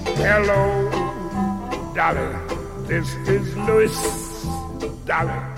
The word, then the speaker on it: Louis: He prefers his name to be pronounced Louis.